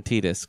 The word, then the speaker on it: Tidus: Yeah, so I just got in an argument with someone about how you say his name, but it's fucking Tidus.